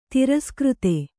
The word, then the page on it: ತಿರಸ್ಕøತಿ: tiraskřti